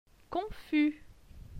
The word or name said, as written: Confus